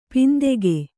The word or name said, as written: ಪಿಂತೆಗೆ